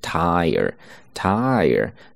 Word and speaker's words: tire: 注意是双元音加元音，发音的时候呢慢一些，饱满一些。 Tire，tire。